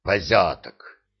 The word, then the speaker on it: позяток: По аналогии с пасынком — позятек или лучше позяток с ударением на втором слоге.